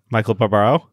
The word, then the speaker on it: Michael Barbaro: Michael Barbaro? – I don't know.